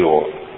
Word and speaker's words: Иова: Ударение по-разному ставить, я привык все-таки Иова. Иов некоторые говорят.